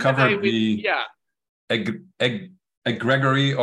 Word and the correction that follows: egregore: or how do you pronounce the word? – Egregore.